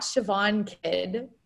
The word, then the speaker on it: Siobhan Kidd: So it'll be a tough one, but my Instagram is at Siobhan Kid, and that's probably a tough one to spell, but it's SIOBHANKIDD. – Yeah. I love that you've changed in your Instagram, like, how to say it.